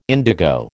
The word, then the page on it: indigo: American English